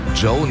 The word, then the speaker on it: Djo: Joe nennt er sich. Und es ist Joe Keery, spielt in Stranger Things. – Ja, und Joe schreibt man D-J-O.